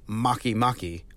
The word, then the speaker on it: Makemake: And then in 2011, they gave that same upgrade to Haumea and Makemake, which is both fun to say and spelled really cool.